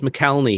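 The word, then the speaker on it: McElhenney: Like the show I was listening to most recently was Rob, I think it's pronounced McKelney.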